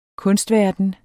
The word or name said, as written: kunstverden